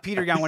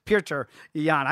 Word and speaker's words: Petr Yan: And first, I love the fact that you have gone with a traditional pronunciation instead of the Americanized bastard version that we do of Petr Yan, when it's Petr Yan. I can't say it as well as you do.